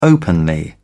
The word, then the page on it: openly: British English